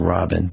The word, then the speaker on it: Robin: And so we bought this used golf cart, and it has a two-cylinder engine, Robin, R-O-B-I-N. It looks like it's spelled Robin, but Robin.